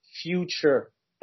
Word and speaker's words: Fewture: Um, if if you Google uh, Fewture model, uh, but not future, uh, it's spelled um, F W. Uh, sorry, F E W T U R E, future.